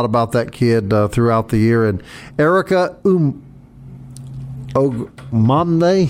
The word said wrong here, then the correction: Ogwumike: Pronounce that for me, Kelly. – Let's see, that would be Ogwumike. Erica Ogwumike.